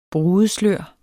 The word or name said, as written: brudeslør